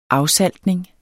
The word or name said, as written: afsaltning